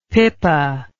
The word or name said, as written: pippa